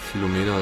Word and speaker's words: Philomena: Oder Philomena? Wie spricht man den aus? – Philomena, ja.